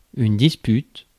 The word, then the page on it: disagreement: [ˌdɪ.sə.ˈɡri.mənt]; /dɪsəˈɡɹiːmənt/